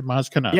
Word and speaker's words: Maz Kanata: Maz Kanata.